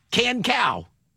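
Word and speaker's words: Kan Cao: Can Cow. Anyway, Can Cow. – Is that her? Right, yeah, that's her. That's her. You got a number? So anyway, are you ready for this? So Can Cow, she is a professor of cell biology and molecular genetics.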